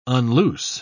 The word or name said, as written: unloose